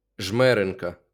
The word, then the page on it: Жмеринка: ˈʒmɛrɪnkɐ; Ukrainian